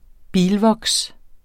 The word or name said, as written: bilvoks